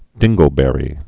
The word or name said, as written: dingleberry